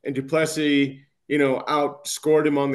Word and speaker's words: Duplessis: The S on the end is silent because of where he comes from. It's Duplessis.